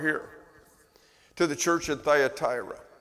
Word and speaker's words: Thyatira: That's the English pronunciation. In Greek, it's Thyatira. Thyatira. But it sounds weird saying that. So I'm going to continue to use the English version because we're more comfortable with that.